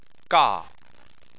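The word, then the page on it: ga: tone 4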